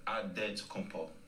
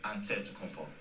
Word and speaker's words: Antetokounmpo: The Nigerian way, you gotta say with a D, so it's Adetokunpo. But in the Greek way, you say with the NT, like Antetokounmpo.